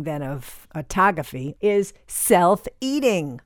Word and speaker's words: autophagy: which is called autophagy. It looks like it's pronounced phonetically. It would be autophagy, but I had to look up how to say it. Autophagy, like photography, sort of.